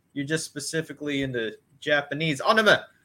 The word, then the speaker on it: anime: In Japan, so why people it's actually anime. Pronounced anime. – Anime.